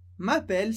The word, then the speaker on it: m'appelle: M'appelle significa me chamo, eu me chamo. Perceba que a pronúncia do primeiro é mais fechada, s'appeler, e a segunda bem aberto, m'appelle, m'appelle.